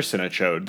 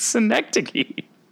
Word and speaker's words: Synecdoche: I hate that you call it that. – That's how it's pronounced. – No, it's not. – It's not a real city. – It's Synecdoche. – Cinechode. – It's not Cinechode.